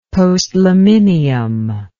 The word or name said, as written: postliminium